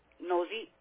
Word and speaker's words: Nosie: Well, the first name, you spell it M-A-R-C-H-A. – Okay. What's the last name, Ms. Martha? – Nosy, N-O-S-I-E. – N-O-S-I-E, okay.